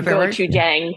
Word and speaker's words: gochujang: Is it gochujang? Is that? I got that gochujang.